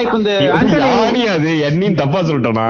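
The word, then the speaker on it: Yanni: ஐயோ நான் கரெக்டா ப்ரொனவுன்ஸ் பண்றேன்னு தெரியல எண்ணியா யானியா யானி யானி யானியா சரி யானியோட எந்த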